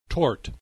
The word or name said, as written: tort